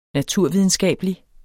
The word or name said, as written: naturvidenskabelig